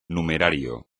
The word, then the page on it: numerario: espagnol